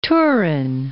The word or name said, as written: Turin